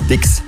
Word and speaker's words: Styx: Die Band heißt Styx. Schon richtig ausgesprochen.